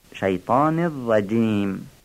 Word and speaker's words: الشیطان الرجیم: از نظر تجویدیم خوبه که این جیم رجیم رو یه مقدار ظریف بگیم، اگر تیز و ظریف بگیم فصیح خواهد بود، شیطان الرجیم. یه نکته بسیار مهم در استعاذه تشدیدهای ره هستش، تشدید شین و ره. اعوذ بالله من الشیطان الرجیم، این دو تشدید رو بعضی وقتا خوب تلفظ نمی کنن.